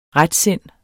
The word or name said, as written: retsind